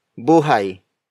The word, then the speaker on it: buhay: Word for word, it's buhay na maganda.